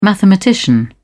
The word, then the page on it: mathematician: British English